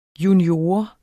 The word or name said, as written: junior